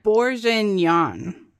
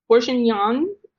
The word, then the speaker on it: bourguignon: Bourguignon. – Bourguignon? All right, that sounds right. – That's what the Google machine told me.